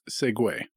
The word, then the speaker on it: segue: Yes, S-E-G-U-E. – What the fuck? – Wait, English. What? – Yeah. – S-E-G-U-E. – It's pronounced Segway.